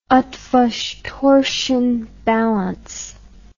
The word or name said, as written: eotvos torsion balance